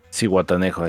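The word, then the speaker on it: Zihuatanejo: Cijatanejo o Cihuatanejo, no sé cómo se pronuncia esa madre. Cihuatanejo, güey. Ciguatanejo, ahí está, güey.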